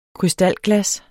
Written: KRYSTALGLAS

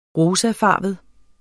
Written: rosafarvet

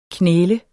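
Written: KNÆLE

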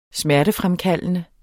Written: smertefremkaldende

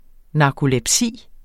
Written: narkolepsi

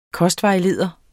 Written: kostvejleder

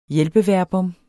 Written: hjælpeverbum

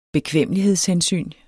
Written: bekvemmelighedshensyn

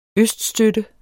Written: øststøtte

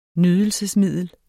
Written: nydelsesmiddel

